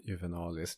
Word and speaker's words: Juvenalis: Satiriken Juvenalis, uttalas det så tror jag. – Säkert. – Juvenalis.